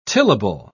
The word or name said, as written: tillable